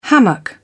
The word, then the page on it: hammock: British English